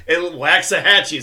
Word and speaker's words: Waxahachie: Waxahachie is what it looks like.